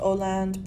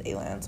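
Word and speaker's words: ALAND: Yeah, so at that Coex mall, we went to A-Land, which I know it's pronounced O-Land, but everyone says A-Land, so I'm just going to say it to you.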